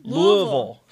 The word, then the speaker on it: Louisville: Louisville. That's the way it's pronounced to the locals.